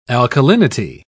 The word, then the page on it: alkalinity: British English